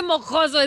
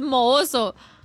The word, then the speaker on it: mohoso: Y no se dice mojoso, es mohoso.